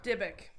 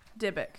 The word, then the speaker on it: Dybbuk: Dybbuk. Dybbuk.